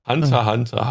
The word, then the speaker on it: Hunter x Hunter: Hunter x Hunter. Hunter x Hunter ausgeschrieben, aber das X wird nicht ausgesprochen.